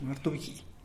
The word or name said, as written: martubgii